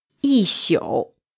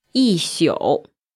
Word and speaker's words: 一宿: It involves a 多音字, and it means a whole night. – 一宿。 – A whole night. – 一宿。